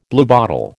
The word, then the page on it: bluebottle: American English